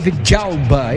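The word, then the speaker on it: Villalba: apareceu por ali o camisa 77 Vilauba com 2 L's, mas a pronúncia é Vidalba, é isso mesmo, Lima? – Isso mesmo, ele é argentino, né? E no argentino, no espanhol em geral, né? 2 L's tem som de J.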